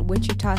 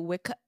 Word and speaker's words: Wichita: Are you in, is it Wichita? Mm-hmm. I can't even pronounce it. Like, Wichita. – Wichita. – Dead, I am dying. – Wichita. – Are you in Wichita? – Oh my god, that has to be one of the funniest pronunciations.